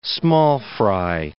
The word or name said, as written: small-fry